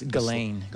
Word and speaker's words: Ghislaine: Or, you know, they were talking about solar storms and all that stuff coming the week of this this amazing trial, this Glacine. I keep on saying her name wrong. It's Ghislaine. Ghislaine Maxwell.